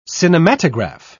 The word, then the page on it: cinematograph: British English